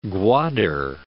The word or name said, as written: Gwadur